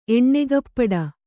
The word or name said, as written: ಎಣ್ಣೆಗಪ್ಪಡ